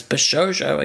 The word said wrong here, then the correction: Bishoujo: Bishoujo, thank you.